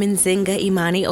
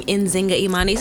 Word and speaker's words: Nzinga Imani: I'm Nzinga Imani. Oh, yes. I think I said that wrong. Nzinga Imani. Sometimes I say it wrong. – It's how you say your name wrong? – Nzinga Imani.